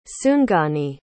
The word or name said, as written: tsungani